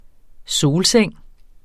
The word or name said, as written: solseng